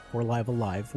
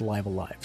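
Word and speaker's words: Live A Live: which that is the official pronunciation. I heard on the Kit and Krista show recently that for a while there was no real official English pronunciation of the game, but somebody at the Nintendo Treehouse said that, well, officially, when it's talked about in English by, you know, people in Japan, it's pronounced Live Alive.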